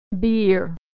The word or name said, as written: beer